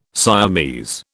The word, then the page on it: Siamese: British English